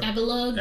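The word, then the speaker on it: Avalugg: But also, when it comes out to boss battles, fighting, how you say it? Avalong, whatever? – Avalug.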